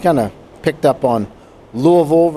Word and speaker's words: Louisville: You know, if you're from Louisville or know of Louisville, many people will pronounce Louisville Louisville or Louisville. That's not how the people in Louisville say it. They say it Louisville, almost like they got a couple marbles in their mouth.